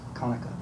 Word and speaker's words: Conecuh: C-O-N-E-C-U-H. Some writers pronounce it as Conecuh, but it is Conecuh.